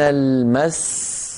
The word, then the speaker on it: المس: من المس أيها الإخوة أصلها من المس من المس فيها سينان سين ساكنة بعدها سين مكسورة لكن لما نقف نقول من المس فلا نستطيع أن ننطق إلا سين واحدة لا نستطيع و وكأنه نقص أو سقط من التلاوة حرف.